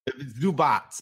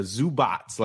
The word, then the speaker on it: Zubac: I'm just impressed with your pronunciation of Ivica Zubac. Was that close? – Zubac. It's the C that gets everybody thrown off. Ivica, I think, is all right, but it's a Zubac, like a TS at the end.